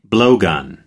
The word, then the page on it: blowgun: American English